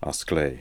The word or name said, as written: Asklay